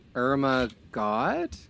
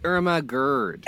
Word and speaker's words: Irma Gerd: Okay, no, not until you say her name correctly. I've had people complain to me about this. No, what did you call her? – Irma God? – No. – Oh, it's Irma Gerd.